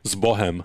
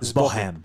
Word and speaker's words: Zbohem: How do you say it again? – Zbohem. – Zbohem. – Yeah.